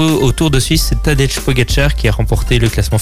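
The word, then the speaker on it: Tadej Pogačar: Tadej Pogacar. Tadej Pogacar.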